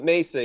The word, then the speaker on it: Mesa: I think it's Mesa. Yeah. Sorry, I'm Spanish pronunciation. But Mesa, yeah.